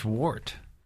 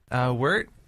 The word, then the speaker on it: wort: What is wort? – Wort. – W-O-R-T. – Yeah, it's spelled wort, which is not very appealing, so it's called wort.